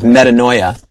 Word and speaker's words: metanoia: We were doing another set of commentaries, and the word here for repent in the New Testament is most often the word metanoia. Metanoia, which sounds like the word paranoia, right?